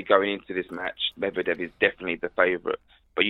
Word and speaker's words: Medvedev: How do you say his name? – Medvedev. – Medvedev, I do apologise, yeah.